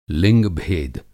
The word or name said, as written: लिंग-भेद